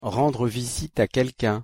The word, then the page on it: rendre visite a quelquun: French